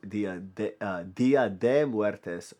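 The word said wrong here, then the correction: Día de los Muertos: or Dia de los Muertes, Dia de los Muertos.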